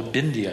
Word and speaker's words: bindi: Perhaps you've seen that's called a bindi, I believe it's pronounced.